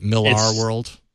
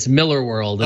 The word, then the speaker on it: Millar: A lot of people make that mistake because it's spelled Millar. – Yeah. – But he is Scottish and it is pronounced Miller.